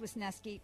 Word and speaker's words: Wisniewski: Pronounced Wisniewski.